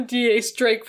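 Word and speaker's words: X Drake: And the thing is that the X is pronounced D-Ace. – Because... No! – Yes. – No! – If you watch the anime... They call him D.A. Drake from the very beginning, despite his name being stylized as an X, but it's supposed to be...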